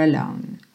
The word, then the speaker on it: alone: Mas o Elton John canta mais Up Here, Up Here Alone, ok? Então, Rocket Man burning out his fuse up here alone. Alone. Não pronunciei a letra E no final, ok? Alone.